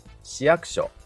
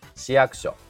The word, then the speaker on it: 市役所: 正しいイントネーションは市役所だと思いますまあ、矢が下がって、えー、区にアクセントがつく形ですね市役所市役所、